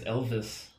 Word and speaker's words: Elwes: Yeah, it's Elvis.